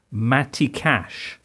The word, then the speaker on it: Matty Cash: One Polish player to watch out for, I'm not quite sure how to pronounce this, but Matty Cash. – Yeah. That's correct.